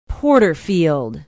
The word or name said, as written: porterfield